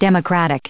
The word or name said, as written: democratic